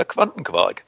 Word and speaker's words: Quantenquark: Relativa Quantenquark.